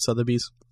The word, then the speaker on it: Sotheby's: Sotheby's? – Yep.